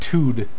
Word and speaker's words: 'tude: I think you mean just tude. – Tude. – Apostrophe T-U-D-E. – Rhyming with dude.